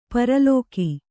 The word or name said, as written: ಪರಲೋಕ